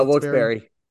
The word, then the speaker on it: Wilkes-Barre: Yeah, Wilkes-Barre.